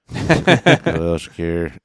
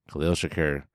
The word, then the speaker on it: Khalil Shakir: It's like if Khalil Mack and Tupac had a baby. Khalil Shakir. Not quite spelled the same, but I think about that every time I see his name. Khalil Shakir, Khalil Mack, and Tupac.